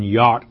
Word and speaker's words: yod: It's jot, J in the English, but pronounced properly, not one yod or tittle of the law.